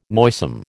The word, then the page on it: noisome: American English